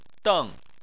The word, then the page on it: deng: 4